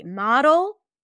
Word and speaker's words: model: It looks the same. The only difference is E versus A, but these are two different words pronounced differently. Model, modal. Model, modal.